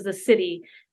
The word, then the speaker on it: siddhi: And then the top is the city, and it's spelled S-I-D-D-H-I.